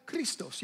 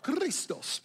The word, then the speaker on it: Christos: Here I said that, Christos. You have to roll your R, Christos.